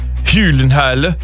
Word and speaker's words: Gyllenhaal: Or as we learned on a chat show in the last couple of years, Jake Gyllenhaal is how you actually say his name, but we'll give him Gyllenhaal.